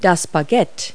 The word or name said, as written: baguette